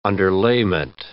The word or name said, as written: underlayment